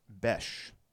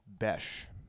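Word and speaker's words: beş: The answer is it is pronounced Besh. Besh. And Larry, Besh.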